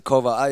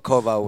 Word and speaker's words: Kowal: I pronounce it Kowal. It's been butchered so many times. always, but Kowal with like a V.